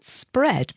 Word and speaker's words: spread: And the word spread may be more difficult to pronounce if you're not used to saying three consonants altogether. You have to make a spr sound, spr, S-P-R, for spread.